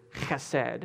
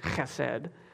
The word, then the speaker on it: chesed: But this special Hebrew word, it's chesed. You got a little phlegm at the front. That's what you got to do to pronounce it, chesed.